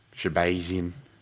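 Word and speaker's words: Shahbazyan: Edmund Shabazian. I don't know why. He's Armenian, Dan. Why do you have to say it like you're fucking from Pennsylvania? Shabazian. -150 versus Brad Tavares, +130.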